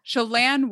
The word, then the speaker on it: Chelan: Is that how you say it? Chelan. Chelan, Washington. – Chelan, Washington. – Chelan, Washington.